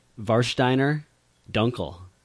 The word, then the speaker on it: Warsteiner Dunkel: It's a Warsteiner Dunkel.